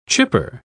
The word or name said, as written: chipper